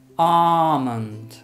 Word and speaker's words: almond: Which you could say in the United States, but the British pronunciation is almond. Almond. And in the United States, I think they do pronounce the L, so it's almond. Almond. In the UK, it's almond.